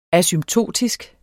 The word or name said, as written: asymptotisk